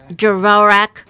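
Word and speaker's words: Dvořák: You've been listening to Dvorak. I said it right when we were not on the air. Dvorak.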